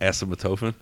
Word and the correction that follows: acetaminophen: Actually, I just read it wrong. Acetaminophen. – Right. – I just switched two letters around, and like if you switch those two letters around, it's asymptotophin.